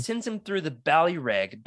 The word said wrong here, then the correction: Balrog: Ba- Ballyreg you kind of like swallow the Y, right? Balrog. – Balrog. I don't know. Roll your R.